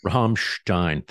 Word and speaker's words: Rammstein: I don't know how you pronounce it. They're Rammstein. – German. Rammstein. Thank you.